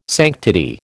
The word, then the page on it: sanctity: American English